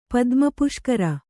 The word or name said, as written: ಪದ್ಮಪುಷ್ಕರ